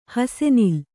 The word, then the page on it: ಹಾಸುನೂಲು: hāsu nūlu